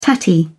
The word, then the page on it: tatty: British English